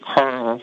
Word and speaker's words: Carl: Yeah, yeah, my site, if everyone would check it out, it's Liberate Carl. That's spelled C-A-R-L, first name, .com.